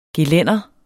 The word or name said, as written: gelænder